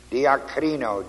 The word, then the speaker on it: diakrino: The word staggered is the word diakrino, D-I-A-K-R-I-N, long O.